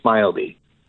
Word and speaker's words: Smildy: Well, it depends where you are. In the U.S., we usually say Smildy.